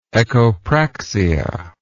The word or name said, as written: echopractic